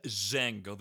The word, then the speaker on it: Django: In Django, the D is silent.